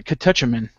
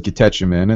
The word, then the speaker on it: catechumen: Tell us about that band, because I hate trying to pronounce it, Catechumen, right? – Yeah, well, that's a on-purpose mispronunciation. It's catechumen. It's a Catholic term.